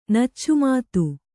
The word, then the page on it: ನಚ್ಚುಮಾತು: kannada